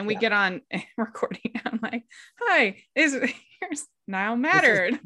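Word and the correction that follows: Niall Matter: Neil Matter.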